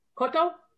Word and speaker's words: Kotto: I never know how to pronounce his name. Kotto. He was 81. Kotto? – Kotto. – Kotto, okay. Yaphet Kotto, who was 81.